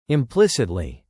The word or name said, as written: implicitly